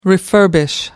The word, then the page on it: refurbishment: American English